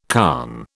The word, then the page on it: conn: British English